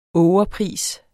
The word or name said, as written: ågerpris